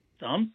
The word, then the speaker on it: Psalms: It is a silent P, and it is the longest book in the Bible. It starts with P, though. What is that book? – Psalms.